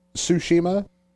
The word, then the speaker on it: Tsushima: So, you know, now I think the way you pronounce it is Ghost of Tsushima. – I'm sold on that being the pronunciation.